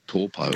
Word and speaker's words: Taupo: When I was in New Zealand, there was Lake Taupo. Now it's called Taupo. – Taupo, yeah, that's right. It's just the vowel pronunciations are a bit different. To, they don't really have that ow sound, like cow, meow.